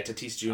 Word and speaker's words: Tatis: Is it Tatis? – Yeah, Tatis Jr. All right, yeah.